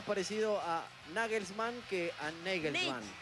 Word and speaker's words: Nagelsmann: Es Nagelsmann. – Claro, es Nagelsmann. – ¿Cómo suena? – Es Nagelsmann, pero yo consulté algunas páginas que...